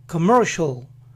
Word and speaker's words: commercial: Para comercial, la primera sílaba es K, K. Pronuncien esta A lo más corta posible. K, K. Ahora ven que a continuación hay dos consonantes juntas, M y R. Esfuércense por pronunciarlas juntas, así. Mer, mer, mer. Entonces este inicio sería kmer, kmer, kmer. Comer. Y la palabra completa entonces es commercial, commercial, commercial. ¿Se dan cuenta que no es comercial, commercial, sino commercial?